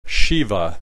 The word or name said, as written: Shiva